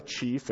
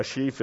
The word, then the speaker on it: sheaf: It's not a chief and it's not a sheep, mispronounced. A sheaf is, you know, like grain, the stalks of grain with the grain actually on it.